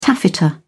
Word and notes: taffeta: British English

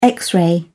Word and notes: x-ray: British English

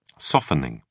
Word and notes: softening: British English

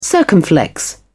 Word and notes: circumflex: British English

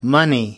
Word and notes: money: American English

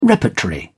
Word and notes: repertory: British English

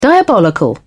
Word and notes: diabolical: British English

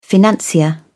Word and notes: financier: British English

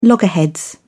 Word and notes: loggerheads: British English

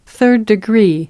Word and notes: third degree: British English

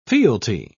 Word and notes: fealty: British English

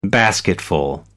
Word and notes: basketful: American English

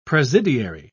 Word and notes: presidiary: British English

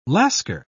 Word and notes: lascar: British English

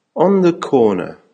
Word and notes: on the corner: American English

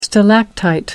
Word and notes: stalactite: American English

